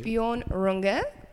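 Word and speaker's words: Björn Runge: Bjorn Runge. Did I say that correctly? – Perfect.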